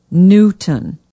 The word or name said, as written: newton